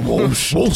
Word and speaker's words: Wolscht: W-O-L-S-C-H-T. Walsht. – Walsht.